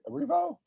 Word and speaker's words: Erivo: Yeah, it's very Arrivo. Did I say that right? Arrivo? – Yeah, you said it right.